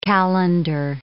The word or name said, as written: calender